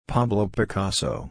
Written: Pablo Picasso

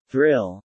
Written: Thrill

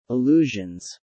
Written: allusions

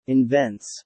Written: invents

